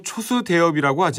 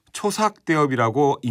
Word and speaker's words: 초삭대엽: 지금은 일반적으로 초수대엽이라고 하지만 이 악곡 제목에 쓰인 수자가 한자로 샘수 또는 빠를 삭으로도 읽히는 글자거든요. 본래는 삭대엽에서 맨 처음 파생된 악곡이라는 의미에서 초삭대엽이라고 읽는 게 맞는 발음이긴 합니다만 초수대엽이라는 제목이 오랜 세월 간습에 의해 익숙하게 굳어져 버린 거죠.